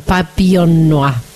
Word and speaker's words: Papillon Noir: Esa es la pronunciación, Papillon Noir. No lo iba a poder pronunciar no, bien, está bien, lo pronunciaste muy bien.